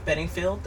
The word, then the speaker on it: Bedingfield: Bedingfield, that's how you pronounce it.